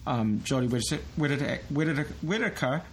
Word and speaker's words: Jodie Whittaker: Jodie Whittaker, right? Am I pronouncing the name right? – Yes.